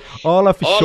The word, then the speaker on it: Olaf Scholz: Olaf Scholz.